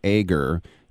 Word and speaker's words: Egger: Eka, or E-G-G-E-R, Eger. You pronounce them like K's.